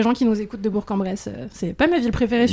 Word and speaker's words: Bourg-en-Bresse: Trouvez-nous les deux autres personnes qui nous écoutent de Bourg-en-Bresse.